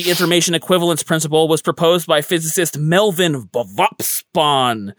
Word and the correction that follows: Vopson: I mispronounced that. Vopson.